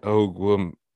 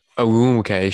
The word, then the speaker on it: Ogwumike: Although they also say in here Draymond Green, Kyle Kuzma, and how do you say Nneka Ogum Ogwumike and uh, they, oh, they're are they are they sisters? – Ogwumike.